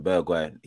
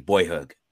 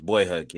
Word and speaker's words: Bergwijn: For me, I'll be honest with you, we're all pronouncing his name wrong, innit? Yeah, you man are calling him Bergwijn. His name's Boyhug, yeah? – Crazy report. – I thought his name was Boyhug, yeah.